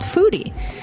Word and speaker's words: Phoodie: It's spelled P-H-O-O-D-I-E. And I'm assuming that's because foodie with an F was already taken. – Yeah, it's based on photography, so... – Oh, foodie with a PH.